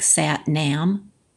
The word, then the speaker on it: Sat Nam: It looks like Sat Nam, but it's pronounced Sat Nam.